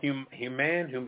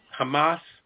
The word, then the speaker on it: Hamas: Humane? If I'm saying it right. – Hamas.